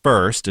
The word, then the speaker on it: Phyrst: And there was this bar on campus, the First. It's spelled PHYRST. – We had one of those.